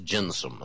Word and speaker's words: gentium: After 200 years, the Senate passed a statute that created another system of law for the foreigners known as the jus gentium, that's spelled G-E-N-T-I-U-M. But it's pronounced gensum, at least according to John.